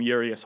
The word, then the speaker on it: Urias: I think it is Urias, like O-O, re, emphasis on that re and then is, but I think I've been calling him Urias a while.